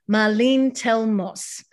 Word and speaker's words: Marlene Telmosse: And today I have with me Marlene Telmosse.